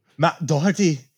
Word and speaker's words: Doherty: You got to say it like a true Irishman. – Matt Doherty.